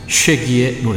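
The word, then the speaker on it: Shaggy: 이 사람 이름은 쉐기의 노래입니다. 같은 자메이카 뮤지션 리카르도 듀센트가 게스트 보컬로 참여했군요. 쉐기의 It Wasn't Me. 노래 읽어주는 남자 백종환 DJ가 다녀가셨습니다. 오늘 전하신 노래는 2000년에 쉐기한테 빌보드 100 싱글 차트 1위를 안겨준 노래죠.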